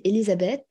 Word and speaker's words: Elisabete: c'est Elisabeth. Ça s'écrit B-E-T-E e à la fin. Donc E-L-I-S-A-B-E-T-E, e e tiré du bas, D-S, comme Dacilea, en fait, tout simplement.